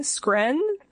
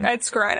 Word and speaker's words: Skrein: It's S-K-R-E-I-N, Skren. Ed Skren. – Is it Skren? – Skrein. – Ed Skrein. Skrein. – Skrein. – No, because you pronounce the first, you pronounce, in German language, you pronounce the second letter. Second letter in the E and the I situation. So I think it's Ed Skrein.